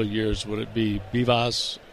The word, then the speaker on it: Vivas: So Bibas, who spells his last name V-I-V-A-S, but he pronounces it Bibas, is now 2-for-2 in this game, and that's five hits for the Nationals.